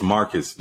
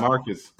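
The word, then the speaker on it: Marques: It's Marcus, not Marquise. I keep telling you that. – It's pronounced Marquise. – It's Marcus. He pronounces it Marcus.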